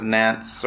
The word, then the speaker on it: Nantes: I have a whole list of like famous edicts that I found online, and the Edict of Nantes, or how do you pronounce that, by the way? – Nantes.